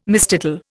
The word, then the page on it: mistitle: Indian